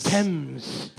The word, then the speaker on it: Thames: Thames. – Thames.